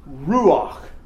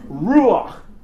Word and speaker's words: ruach: But the Hebrew word for spirit is the word ruach. And that's how you pronounce it. It's almost as though you're clearing your throat. It's a very guttural sound. Ruach.